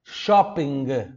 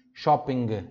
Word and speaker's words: shopping: Tem que, tem que ter o som do G no final. Tá bom? Eu adoro isso na língua italiana. Isso é gostoso, porque eles realmente é, pronunciam a palavra, mesmo que seja uma, mesmo que seja uma palavra de fora, eles, eles trazem para a língua italiana, meio, meio que colocando a, forçando a pronúncia deles em cima da palavra, que é uma palavra estrangeira, né? Shopping. Então eles falam sim o G.